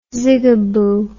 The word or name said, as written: zigaboo